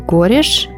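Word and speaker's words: Goresh: А, кстати, в этот момент фотограф Пол Гориш, здесь правильное ударение поставила, сделал снимок э, Леннона и Чепмана когда, собственно, Джон Леннон ставил свою подпись.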